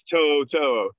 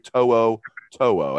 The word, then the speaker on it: To'o To'o: With Polynesian names, they pronounce the vowels. That's what you need to remember. They pronounce the vowels, and they they pronounce hard vowels. So Henry To'o To'o, as you said.